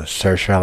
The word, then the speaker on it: Saoirse: Saoirse, like Inertia Ronan, Emma Watson, Florence Pugh.